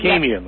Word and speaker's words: Camion: This is it Camion? Is that how you say this one, Detman? – Camion Detman. – Camion, yes. Long A.